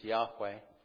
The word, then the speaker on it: Yahweh: Scholars pronounce it these days as Yahweh.